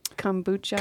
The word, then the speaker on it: kombucha: So first question, did I say it right? Kombucha? – Kombucha. Yeah, that's how you say it, kombucha.